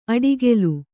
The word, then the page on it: ಅಡಿಗೆಲು: aḍigelu